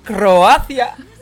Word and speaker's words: Croacia: 嗯，蝌蚪去哪里变成青蛙呢？我不知道， ¡A Croacia!